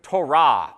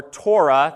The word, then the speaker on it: Torah: The Hebrew word for what those first 5 books are called is the word Torah or Torah. That's what it was called when I was growing up. And then Ben decided to pronounce it the Hebrew way.